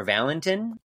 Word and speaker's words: Valentin: John Valentine or Valentin? – Valentin.